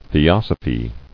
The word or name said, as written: Theosopher